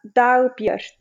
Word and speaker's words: Dagbjört: Uh, Dagbert. Dagbert. Not good? The J the J is more. Okay. It's like Dagbert. Biert. Exactly.